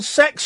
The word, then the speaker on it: CeX: I'm going to pop into my local sex shop. That's how it's pronounced, CEX.